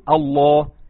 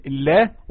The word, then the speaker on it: الله: بسم الله، اللام هنا هي مرققة جدا. طب امتى تخرج؟ طب ازاي انطق اللام الفخمة؟ تقول الله. شوف الفرق بين الله والله، الله والله، هو ده الفرق بين اللام المرققة واللام الفخمة.